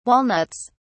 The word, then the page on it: walnuts: British English